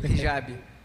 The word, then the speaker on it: hijab: Eu cheguei na cidade, vi algumas mulheres usando o Eihab, pronúncia está correta? Como é que é, isso aí? Você que é um grande conhecedor. – Hijab. Hijab, usando Hijab.